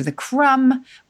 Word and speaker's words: crumb: Crumble, of course, related to the crumb with the silent B. The B was added in the 16th century. It didn't have one originally. I think it followed dumb, where we have the final B, but again, don't pronounce it anymore.